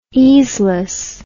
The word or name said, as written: easeless